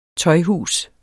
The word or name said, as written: tøjhus